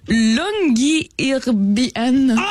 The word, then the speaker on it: Longyearbyen: Ça s'appelle Longyearbyen.